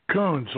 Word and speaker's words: Kuhns: Kunz, like raccoon.